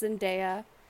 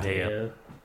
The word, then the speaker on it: Zendaya: and it's pronounced Zendaya. Yeah. – Zendaya.